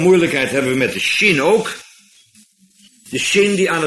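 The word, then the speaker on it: Shin (שׁין): En nou is het zo, als er hier aan de rechterkant een puntje boven staat, dan wordt die uitgesproken als SJ of SCH, Shin. En als die aan de linkerkant staat, dan is het een Sin. Dus zonder J erachter.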